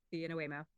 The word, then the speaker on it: Waymo: Waymo.